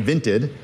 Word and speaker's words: Vinted: Vinted. – Can you spell that? Can you spell that for me? – Vinted, V-I-N-T-E-D. – Okay. – Vinted. – Like Vented with an I. – Exactly. – Like vintage.